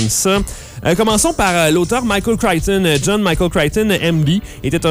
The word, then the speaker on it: Crichton: On prononce souvent Crichton, C-R-I-C-H-T-O-N.